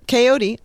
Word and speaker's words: Coyote: So, Coyote, like peyote.